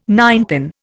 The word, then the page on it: ninepin: Indian